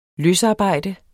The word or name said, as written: løsarbejde